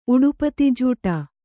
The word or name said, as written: ಉಡುಪತಿಜೂಟ